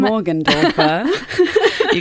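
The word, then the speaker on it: Morgendorffer: Morgan Dorfer.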